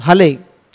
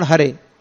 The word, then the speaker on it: Hare: You naturally chant Hale, not Hare.